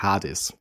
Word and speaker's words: Hades: Oder um ein Computerspiel, und zwar ganz konkret um das Spiel Hades, also englisch ausgesprochen Hades.